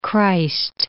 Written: Jesus Christ

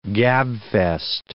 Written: gabfest

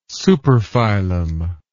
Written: superphylum

